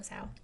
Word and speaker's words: Zhao: Excuse me if I'm saying that wrong. I'm so sorry. – Zhao.